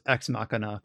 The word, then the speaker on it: Ex Machina: Of course, it's pronounced Ex Machina because it's supposed to be like Deus Ex Machina.